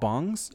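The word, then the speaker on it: PC bang: No, it's not like ONG. – Well, I know, but it's pronounced PC bongs. Same. – It's year. It's a year.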